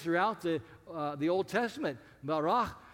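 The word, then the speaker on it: barach (ברא): And if you say it like a Klingon, you're close. Barach.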